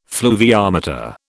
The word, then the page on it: fluviometer: British English